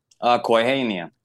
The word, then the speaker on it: Kwaihinian: director, and then again, how do you pronounce the company again? – Kwaihinian. – Kwaihinian is spelled.